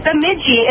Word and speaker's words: Bemidji: It's Bemidji, right? Isn't that how it's pronounced? – It's Bemidji, and it's a Native American.